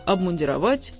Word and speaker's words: обмундировать: Солдаты обмундированы или обмундированы? Откуда такой вопрос, в общем, понятно? Мундир – значит обмундированы. Логично? Логично. Однако логика эта в данном случае порочная. Ударение с корня уходит. Обмундировать. Обмундироваться, обмундирование, обмундированный.